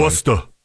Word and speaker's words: Worcester: A city council meeting is underway, and a man went to the, I'm going to try to say this right, Worcester City. – Yeah, Worcester. – They pronounce things weird. – Yeah, Worcester.